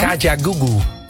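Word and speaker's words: Kajagoogoo: Kaja Gugu.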